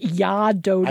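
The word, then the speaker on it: Jag är döden: Jag Ardoden is pronounced Ya Doden.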